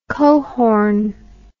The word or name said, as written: coehorn